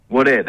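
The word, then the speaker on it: Wadeye: And tell me if I'm saying that right, or is it Wodeyar? – Wodeyar, there you go.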